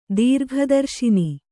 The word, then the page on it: ದೀರ್ಘದರ್ಶಿನಿ: Kannada